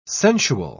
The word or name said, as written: sensual